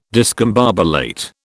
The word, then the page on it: discombobulate: British English